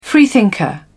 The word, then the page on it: freethinker: British English